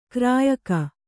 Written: ಗೋರಿಯಿಕ್ಕು

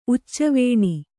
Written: ಉಚ್ಚವೇಣಿ

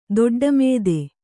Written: ದೊಡ್ಡಮಾತು

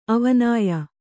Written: ಅವಿನಯ

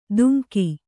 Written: ತುಂಗಿ

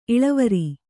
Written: ಇಳುವರಿ

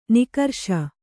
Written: ನಕಾರಿಸು

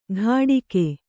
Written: ಘಾಡಿಕೆ

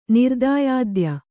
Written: ನಿರ್ದಾಯಾದ್ಯ

